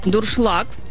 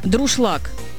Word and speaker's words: дуршлаг: Юля написала нам, что ее бесит, когда говорят неправильно слово «дуршлаг». «Дуршлаг» правильно, а многие произносят как коверка «друшлаг».